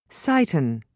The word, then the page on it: cyton: British English